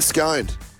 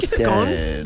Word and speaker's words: Scone: I'm in Scone. – Scone. – And I'm not saying that just being fancy. It's how you say it. – Yeah. – Scone. – Not Scone.